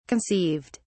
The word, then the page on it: conceived: British English